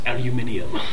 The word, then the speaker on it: aluminium: Lieutenant Wilhelm is like, aluminum. You bourgeois Americans and your fancy medals. He's not very good at this. He takes another shot at you and it pings off your bat. – It's pronounced aluminium.